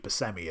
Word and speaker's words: Buscemi: Whether it's Buscemi, or Buscemi, or Buscemi as it's pronounced in Sicily. The way I see it, the world knows him as Buscemi, and even he said that it can be either and that he had to go to Sicily to find out he'd been pronouncing his name wrong, but at the end of the day, the guy knows how to pronounce his own name, so Steve Buscemi it is.